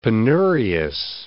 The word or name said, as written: penurious